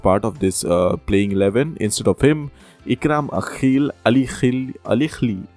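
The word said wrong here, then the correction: Ikram Alikhil: I'm not pronouncing it, Ali Khil, okay. Ikram Ali Khil was taken, and he also did not manage to impress anyone.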